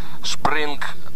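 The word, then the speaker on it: Spring: «Шпринг», она звучит по-немецки, пишется она так же, как английское слово «спринг» – «весна».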